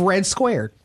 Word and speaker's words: Redd Squared: And he's also the engineer and the innovator of Redd Squared. – I think that's correct. – Did I say that right? – You did.